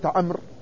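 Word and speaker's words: عمرو: مثل كلمة عمر يكتب فيها واو زائدة ولا تنطق.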